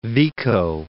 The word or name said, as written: Vico